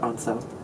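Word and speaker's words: Ancel: A-N-C-E-L-K-E-Y-S. Not far off from Insel. – Or Ansel, maybe, is how you say it? – No, he said Ansel. – Okay. – The documentary said Ansel.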